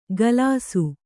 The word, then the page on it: ಗಲಾಸು: Kannada